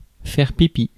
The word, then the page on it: wee: /wiː/; American English; [wiː]